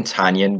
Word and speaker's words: Tonyan: And I shouldn't say I hate saying Tonyan now, but like, I feel like I have to, cause I know they came out with it saying that's how you say it. And it feels so weird. Cause I call him Tonyan forever. – I was like reading it and I'm like, I feel like I said Tonyan, but I don't know.